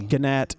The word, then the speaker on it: gnat: Nat. Silent G. – That's really crazy. – Gnat.